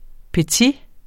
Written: petit

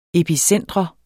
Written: epicentrum

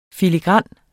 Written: filigran

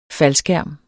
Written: faldskærm

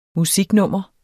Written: musiknummer

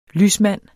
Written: lysmand

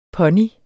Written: pony

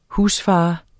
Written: husfar

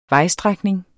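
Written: VEJSTRÆKNING

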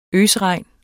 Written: øsregn

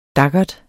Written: daggert

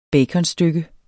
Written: baconstykke

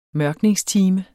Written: mørkningstime

